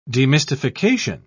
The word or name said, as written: demystification